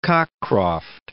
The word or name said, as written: Cockcroft